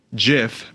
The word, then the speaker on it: GIF: The Oxford Dictionary, it was the word of the year in 2012, and it says GIF may be pronounced with either a soft G as in giant or a hard G as in good. Everybody wins then.